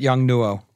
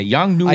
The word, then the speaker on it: Yongnuo: How do you pronounce that? – I pronounce it Yongnuo. – Okay, Yongnuo.